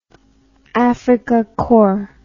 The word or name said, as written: afrikakorps